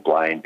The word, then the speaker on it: Blaine: and my surname Blaine B L A I N E.